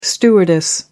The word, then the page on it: stewardess: American English